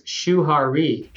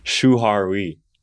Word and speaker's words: Shuhari: What does the Japanese saying shuwari mean to you? Did I say that correctly? – Close. Shuhari. – Shuhari.